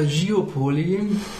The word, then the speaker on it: Geopoly: Das, ausgesprochen heißt das sogar Geopoly, weil es ähm, Englisch ist.